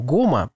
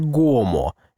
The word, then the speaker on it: гома: Главное, не думайте, что ритуал гома произносится как гомо. Нет, это ритуал гома, а на конце.